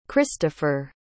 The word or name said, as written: christoffer